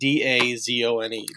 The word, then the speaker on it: DAZN: Yes, it is definitely like DAZNE.